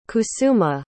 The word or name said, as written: kusuma